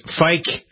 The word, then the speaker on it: Ficke: The website is Fike. I'm going to spell it out because it doesn't sound like it's spelled. F-I-C-K-E, cattle, all one word, .com.